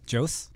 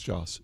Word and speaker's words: Joss: Jose? – Jose. I think it's Joss. – Really? – Yeah. Huh. He told me it rhymes with boss.